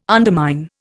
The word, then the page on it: undermine: Indian